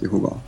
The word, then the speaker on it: Jehova: Men, men de, de själva är enligt de officiella korrekta uttalet Jehova.